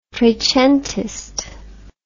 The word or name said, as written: trecentist